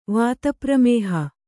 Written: ವಾತಪ್ರಮೇಹ

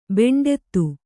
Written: ಬೆಂಡೆತ್ತು

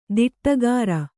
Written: ದಿಟ್ಟಗಾರ

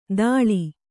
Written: ದಾಳಿ

